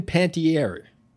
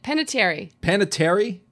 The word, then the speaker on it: Panettiere: Hayden Panettiere. Did I pronounce that correct? – Panettiere.